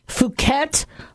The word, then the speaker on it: Phuket: The H3N2, Darwin A strain, the Austria Victoria lineage type B, 2021-like virus, and the Phuket, not short U, let's use the long U, Phuket lineage B, Yamagata lineage of the 2013-like virus.